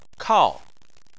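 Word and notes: kao: tone 4

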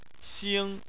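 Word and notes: xing: xing1; tone 1

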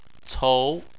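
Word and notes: cou: tone 2

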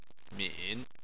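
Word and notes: min3: tone 3

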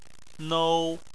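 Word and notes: nou: tone 1

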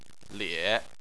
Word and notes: lie: 3

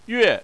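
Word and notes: yue: tone 4

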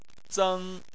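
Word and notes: zeng: tone 1

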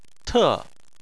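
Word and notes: te: tone 4

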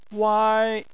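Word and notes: wai: wai1; tone 1

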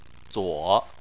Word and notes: zuo: 3

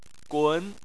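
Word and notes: gun: tone 2

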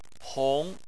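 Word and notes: hong: tone 2

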